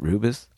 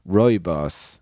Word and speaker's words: Rooibos: Is that how you pronounce it? Rubus? – R-O-O-B-I-S. – Rooibos.